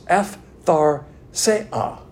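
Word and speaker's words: aphtharsia: It's A-P-H-T-H-A-R-S-I-A. In the Greek, when you have a P-H and a T-H, kind of a pathatha, but it's pronounced F-thar-se-a, which means no ending.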